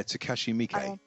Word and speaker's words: Takashi Miike: Yeah, Takashi Miike. – Takashi Miike.